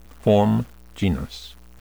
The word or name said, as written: form genus